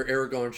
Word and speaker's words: Aragorn: when he gets starts to get hit by arrows to when Aragon or Aragorn shows up.